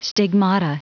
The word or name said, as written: stigmata